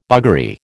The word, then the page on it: buggery: American English